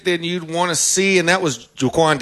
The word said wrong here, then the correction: Joaquin: Joaquin Davis, like Joaquin Phoenix, spelled the same way.